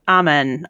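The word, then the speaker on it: Amun: What is ammonia from a- Ammon? Ammon, oh, what, however you say it. Amen. Amen. Amen. Yeah, there we go.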